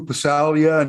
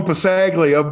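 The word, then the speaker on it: Passaglia: You got some of the Catholic schools where there's some BC Lions history there with the likes of Lou Pasalia. And I just pulled a Halsey on that pronunciation. – And you're correct, by the way. – I used to call him Pasaglia, but he was 3 years ahead of me when I was at St. Thomas More.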